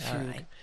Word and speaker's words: fugue: Let me spell that for our listeners, F-U-G-U-E. – That's correct, fougue.